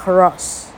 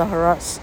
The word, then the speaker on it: hros: 一看哟，好短呐，哈，叫做 h r o s，啊，应该是念作 hross，hross，哈，这个 h 或或者发音或者不发音，像法文的话，h 很多是不发音的，哈。那老克呢？实际上德文也没有多坚强了哈，只是这样，呃，老克就自己决定念作 Hross 哈。